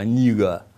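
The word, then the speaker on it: nigga: Слово «негр», они там в тамошнем просторечии, оно произносится как «нигер», не «негро», а «нига».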